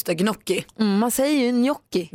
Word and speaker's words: gnocchi: Fast det är rätt att säga gnocchi.